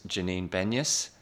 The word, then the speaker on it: Janine Benyus: Her name is Janine Benyus.